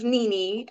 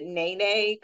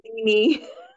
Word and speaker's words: Nene: It's good, and it's Nene. I know people pronounce it Nene because of the E, but it's Nene. – Hi, Nene. I go through the same thing. My last name is H-E-I-D-E, and people call me Heidi.